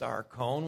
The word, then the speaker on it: archon: It's archon, pronounced archon, which means ruler.